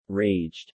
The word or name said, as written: raged